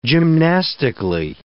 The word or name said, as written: gymnastically